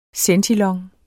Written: centilong

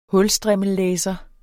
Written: hulstrimmellæser

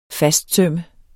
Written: fastsømme

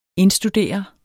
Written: indstudere

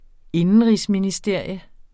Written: INDENRIGSMINISTERIUM

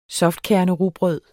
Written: softkernerugbrød

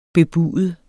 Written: bebude

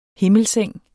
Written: himmelseng

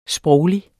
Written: SPROGLIGT

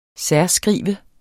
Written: særskrive

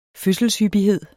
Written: fødselshyppighed

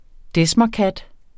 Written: desmerkat

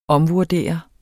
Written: omvurdere

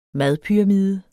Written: madpyramide

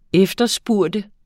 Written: efterspørge

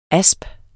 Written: asp

